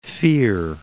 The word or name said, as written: Cape Fear